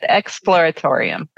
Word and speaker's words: Exploratorium: or Explorium, I guess it is. How do you say that? Exploratorium? – Yeah, just slow down a little bit. Exploratorium.